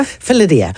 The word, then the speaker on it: Felidia: I hope I'm pronouncing it right. Felidia? – Felidia.